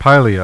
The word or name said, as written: Pylia